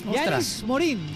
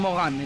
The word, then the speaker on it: Yanis Morin: Se dice como se lee. Yanis Morin. Yanis Morin. Así. – Sí. – Nada de pronunciación a la francesa. Yanis Morin. – Nada de Mogán, entonces.